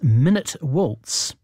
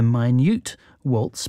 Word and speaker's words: Minute: Chopin's famous minute waltz, which is doesn't take a minute to play. It was originally named the Minute Waltz, but we tend to mispronounce it as the Minute Waltz.